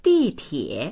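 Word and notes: di4tie3: Mandarin

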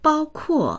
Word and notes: 包括: bāokuò

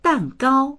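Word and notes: dan4gao1: Mandarin

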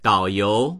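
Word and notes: dao3you2: Mandarin

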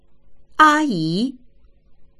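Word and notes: 阿姨: āyí; a1yi2

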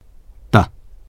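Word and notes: de: Mandarin

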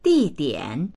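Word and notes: di4dian3: Mandarin

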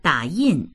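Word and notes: da3yin4: Mandarin

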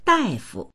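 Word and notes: dai4fu: Mandarin

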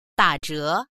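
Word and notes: da3zhe2: Mandarin

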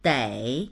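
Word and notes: dei3: Mandarin

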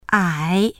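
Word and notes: 矮: ǎi; ai3

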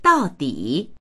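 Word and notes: dao4di3: Mandarin

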